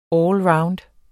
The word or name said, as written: allround